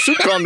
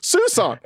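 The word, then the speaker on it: soupçon: I read this word, I said, well, that looks like soup con to me. Not once has anyone said it's Susan. How the fuck would I know that it's pronounced Susan?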